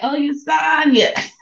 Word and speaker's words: Oyesanya: I used to tell people when I was little, like, it rhymes with Sanya. So I'm like, Oyesanya.